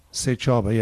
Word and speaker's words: Sechaba: Sechaba, yeah.